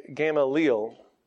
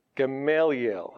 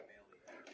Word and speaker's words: Gamaliel: But to a certain extent, I like this guy, Gamaliel, because he's got a lot. I'm mispronouncing it, aren't I? Yes, what is it supposed to be? – Gamaliel. – I should have practiced that. Gamaliel.